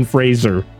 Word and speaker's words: Fraser: Fraser. – Rhymes with Razor. – Rhymes, yes.